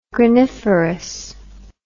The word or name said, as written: graniferous